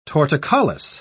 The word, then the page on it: torticollis: British English